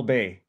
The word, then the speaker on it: Bae: My name is Paul Bae. – As in Bae, B-A-E. – As in Bae, B-A-E. It's Korean, so it's actually pronounced Bae, which is how we say stomach or tummy.